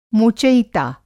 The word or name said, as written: मोचयिता